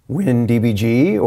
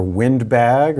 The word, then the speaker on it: WinDbg: namely WinDBG or WindBag or WinDebug. All these pronunciations I think are valid.